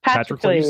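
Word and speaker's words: Patroclus: Patrocles. – Patrocles.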